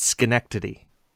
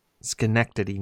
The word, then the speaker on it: Schenectady: For everybody who's wondering, how do you say that city? It's Schenectady. Schenectady, New York.